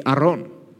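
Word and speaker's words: Aron (ארון): Today we're looking at the ark in Hebrew, in Exodus, which in Hebrew is pronounced the Aron. The Aron.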